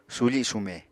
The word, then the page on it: sufi sume: Apatani